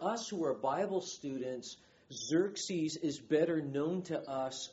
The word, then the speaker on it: Xerxes: Xerxes, his name is spelled X-E-R-X-E-S. Kind of sounds like a coffee machine. Xerxes, X-E-R-X-E-S.